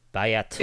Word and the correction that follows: bayt: Bait. Bait. I, I, say aleph and bait.